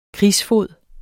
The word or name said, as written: krigsfod